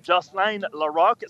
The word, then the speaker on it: Jocelyne Larocque: Jocelyne Larocque, on doit prononcer Jocelyne Larocque. C'est comme ça qu'on prononce son nom véritablement ici.